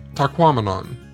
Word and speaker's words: Tahquamenon: But, as I learned from a sign within the park, one easy way to get it right is to remember that it rhymes with phenomenon, as in Tahquamenon.